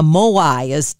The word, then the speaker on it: Moai: I was intrigued by finding a Blue Zones buddy or creating a Moai.